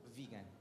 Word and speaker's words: Vigan: Vigan.